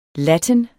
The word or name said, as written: latin